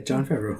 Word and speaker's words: Jon Favreau: One is, Jon Favreau, is that his name? – Yes. – I think that's how you pronounce it. – Yeah, Jon Favreau.